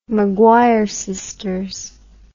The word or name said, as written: McGuire Sisters